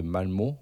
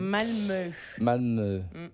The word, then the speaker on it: Malmö: Donc du coup là on est sur un groupe totalement suédois qui vient de Malmö en Suède. – Malmö. Il y a un petit tréma.